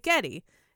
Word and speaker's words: Geddy: So when she'd say his name, it sounded like Getty.